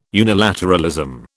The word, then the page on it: unilateralism: British English